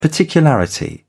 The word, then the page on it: particularity: British English